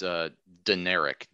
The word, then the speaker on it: Deneric: I said Demeric. Is it Deneric or Demeric Prince? I don't want to say it wrong because he's a good player. – It's Deneric. D-E-N-E-R-I-C. Okay.